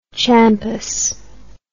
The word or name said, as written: champus